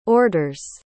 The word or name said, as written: orders